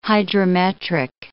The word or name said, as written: hydrometric